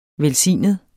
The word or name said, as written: velsignet